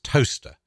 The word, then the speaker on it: Towcester: David did remind me, I called it Towcester, it's pronounced toaster.